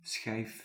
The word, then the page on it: slice: /ˈslɑɪs/